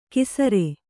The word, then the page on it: ಕಿಸರೆ: kisare